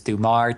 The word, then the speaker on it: Thumar: Last name is Thumar, T H U M for Michael, A R.